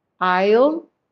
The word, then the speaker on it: I'll: I'll cuando hacemos la contracción debe de sonar muy bien la L.